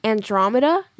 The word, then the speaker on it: Andromeda: Andromeda? – Yeah.